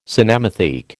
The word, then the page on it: cinematheque: American English